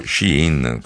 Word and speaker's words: Shein: Perché Shein, o Shein come si dovrebbe pronunciare,